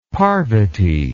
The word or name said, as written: Parvati